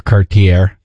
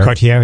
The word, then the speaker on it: Cartier: Near Cartier. Is that how you say it? Cartier? – Cartier, yeah. – I think it's Cartier.